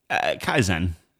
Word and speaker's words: Kaisen: Kaisen rhymes with 10.